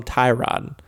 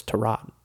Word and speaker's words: Tyrod: Because everyone always called him Tyrod. And then when that Hard Knocks season came out, it was corrected. Everyone was like, oh, it's Tyrod.